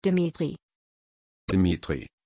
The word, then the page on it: Dmitri: German